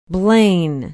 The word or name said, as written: blaine